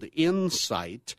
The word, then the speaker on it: Incyte: This one's called Incyte. I-N-C-Y is a symbol. And it's spelled I-N-C-Y-T-E. Okay. So we're not trying to incite violence or anything like that.